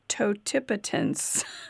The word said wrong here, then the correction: totipotence: T-O-T-I. – You did not say it right. – T-O-T-I-P-O-T-E-N-C-E. – Right, totipotence.